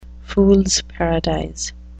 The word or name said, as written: fool's paradise